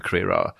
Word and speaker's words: Crerar: I've just got a cup of tea and I'm sitting down with Pippa Crerar, the political editor of The Guardian, whose surname I've pronounced right, haven't I? – Brilliant. I'm delighted.